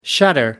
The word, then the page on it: shutter: American English